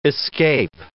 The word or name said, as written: escape